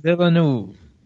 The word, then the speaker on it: Villeneuve: Denis uh, Villeneuve. There you go. Denny Villeneuve.